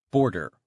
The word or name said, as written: border